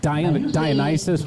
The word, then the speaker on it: Dionysus: Like Dionysus would rhyme with crisis and ISIS.